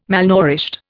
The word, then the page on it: malnourished: Indian